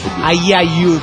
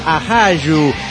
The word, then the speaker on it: ajayu: Usted se confundió con la palabra alerra, la desintegrada desaguadero, ya que no es ayayu, como dijo usted, sino que es ajayu. Y a lo mejor lo estoy pronunciando mal. Aquí es A-J-A-Y-U.